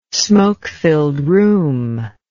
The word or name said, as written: smoke-filled room